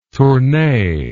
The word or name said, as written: Tournai